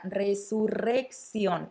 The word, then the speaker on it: resurrección: Mucho cuidado con esta palabra resurrección, con la pronunciación. Creo que es un buen ejercicio para practicar el sonido de la doble R, resurrección.